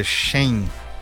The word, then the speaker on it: Shein: Eu falei certo o nome da empresa? É Shein. Como é que fala? Shein, não é, é isso? Shein, desculpa. AliExpress, né? Tá certo. Foi bem. – Não, mas a primeira é Shein. É, Shein.